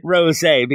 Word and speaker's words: Rosé: Rosé, because of the little accent on the E.